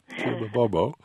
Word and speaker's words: Kim Bobo: Yes, you're on the air with Kim Bobo from Interfaith Worker Justice. I might say that that's spelled B-O-B-O, Kim Bobo.